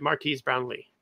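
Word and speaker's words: Marques Brownlee: We talk about, if you guys have ever heard of, I'm sure, hopefully I'm saying his name right, Marquise Brownlee.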